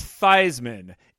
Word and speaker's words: Theismann: And the guy from Notre Dame, as the story went, said, No, it's Theismann. It rhymes with Heisman.